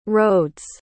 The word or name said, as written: rhodes